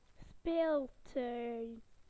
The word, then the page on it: playground: British English